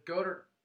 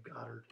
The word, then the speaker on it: Goddard: the the Goddard drop that should have been a touchdown. I'm gonna correct you there and say Goddard.